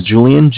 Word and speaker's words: Julian: It's Julian. My name is Julian, J-U-L-I-A-N.